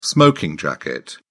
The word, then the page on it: smoking jacket: British English